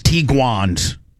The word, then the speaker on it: Tiguans: we're getting one of these Tiguans.